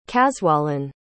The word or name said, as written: caswallan